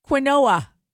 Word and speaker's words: quinoa: Mike, quinoa.